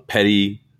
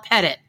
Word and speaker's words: petit: Do you say, is it P-E-T-I-T, but it's like a petty larceny? – Yes. And in fact, I took some French, so it would be a petit waiver, but that's not how we pronounce it in the South. – I know. Well, we pronounce it as petty. – Yes, we call it Pettit. It's good old Pettit waiver in Atlanta.